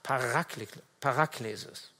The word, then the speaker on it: Paraklesis: Und das griechische Wort, was er verwendet, lautet Paraklesis. Also die Betonung liegt auf dem zweiten A, Paraklesis.